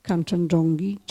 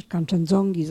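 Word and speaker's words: Kanczendzongi: zostaje 300 metrów pod szczytem Kanchenjongi, czy Kanchenjongi, zależy jak to wymawia.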